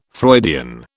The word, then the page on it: Freudian: British English